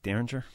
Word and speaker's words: Derringer: Derringer? – Yeah.